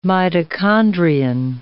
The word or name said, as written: mitochondrion